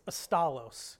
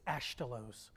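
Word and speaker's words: Asztalos: His last name is Astalos, but it's truly pronounced, they remove words when they came to the United States, it's Ashtalos, which means cabinet maker or woodworker.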